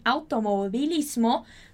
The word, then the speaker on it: automovilismo: Aqui a gente está falando das corridas, quando a gente fala de automobilismo. Muito cuidado que a gente escreve com um V e pronuncia com esse B bem sutil.